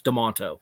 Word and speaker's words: Demento: Um, it's pronounced De- it's spelled Demento, it's pronounced Demonto.